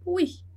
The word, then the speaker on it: oui: Em seguida, a gente tem a palavra oui. Oui é sim. E a gente fala oui. Oui. Oui. Né? Então, ali sem segredo, o e o u, som de u. Oui.